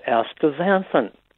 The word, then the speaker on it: astaxanthin: Okay, that's how you say that. I was like, is it astaxanthin? – Astaxanthin. – I was like, how do you say it? I've never heard anyone say it before.